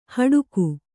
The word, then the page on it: ಹಡುಕು: haḍuku